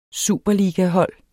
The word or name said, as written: superligahold